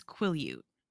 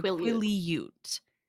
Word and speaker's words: Quileute: It's Quileute. So Quileute. Is the breakdown of it. Yeah. – Quileute. – Yeah. So that is how to properly pronounce the tribe.